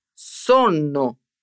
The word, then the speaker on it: sonno: Em italiano, escrevemos essas palavras com dois N, Sonno. Repara só na diferença da pronúncia.